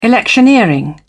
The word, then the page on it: electioneering: British English